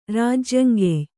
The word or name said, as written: ರಾಜ್ಯಂಗೆಯ್